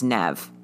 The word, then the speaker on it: Neve: It's it's has been left to her and her sister, her older sister, whose name we assume is Neve, like Neve Campbell. Yeah, because um, it's spelled N E V E.